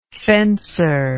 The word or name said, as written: fencer